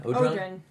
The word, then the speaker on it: Odron: Odron. – Hello. – How do you pronounce it? Odron? – Odron.